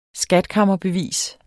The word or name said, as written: skatkammerbevis